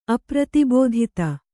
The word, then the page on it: ಅಪ್ರತಿಬೋಧಿತ: apratibōdhita; Kannada